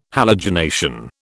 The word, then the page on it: halogenation: British English